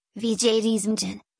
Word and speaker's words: Wiedźmin: The Witcher, Polish, Wiedźmin, Polish pronunciation, Wiedźmin, is a fantasy series of novels and short stories written by Polish author Andrzej Sapkowski.